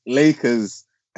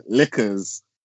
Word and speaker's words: Lakers: Cause it's Lakers and you're saying liquors.